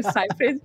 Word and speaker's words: Seyfried: Seyfried, ou Seyfried, aquela não, é Seyfried.